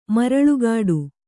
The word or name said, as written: ಮರಳುಗಾಡು